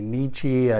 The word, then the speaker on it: Nietzsche: However you want to pronounce his name, a lot of us say Nietzsche. I think it's supposed to be Nietzsche.